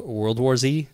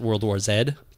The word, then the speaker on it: World War Z: I struggle with the book World War Z because I want to pronounce it World War Zed, but I know that that's not really what Max Brooks would call it.